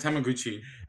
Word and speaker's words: Hamaguchi: It's Hamaguchi.